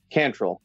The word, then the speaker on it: Cantrell: And it's a Cantrell, accents on the first syllable.